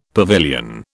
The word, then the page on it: pavilion: British English